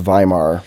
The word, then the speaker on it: Weimar: I said the Weimar Republic. It's pronounced Weimar.